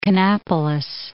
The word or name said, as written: Kannapolis